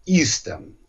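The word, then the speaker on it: Eastham: Там находится городок Истон, который пишется как Истхэм, но произносится Истон.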